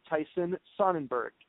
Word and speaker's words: Sonnenburg: And on the phone, I've got current manager uh, Tyson Sonnenburg.